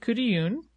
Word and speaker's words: Khutulun: So, for her name pronunciation, we are going with Khutulun. If that's not the way you like to hear it said, we're sorry, but that's the scoop.